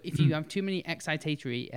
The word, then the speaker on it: excitatory: Excitatory, yeah.